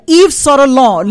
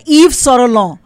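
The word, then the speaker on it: Yves Saint Laurent: ईव सोरलॉन लिखा जा रहा है येव्स ऑफ सेंट लॉरेंट और उसको पढ़ा जा रहा है ईव सोरलॉन.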